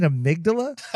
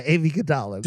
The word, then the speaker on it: amygdala: And she was like, wait, you mean Amygdala? And I was like, yeah, Amy Gadala. – Did he know that he was saying it wrong? – Have you met OB?